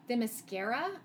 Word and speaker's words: Themyscira: Oh, so now people know her island as Themyscira, I think is how it's pronounced.